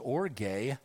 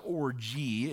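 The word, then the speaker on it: Orge (ὀργή): Orge is how it's pronounced. No, it's not orgy. It's orge, and it means the wrath of God against open and unrepented of sin, willful displeasure towards the things of God.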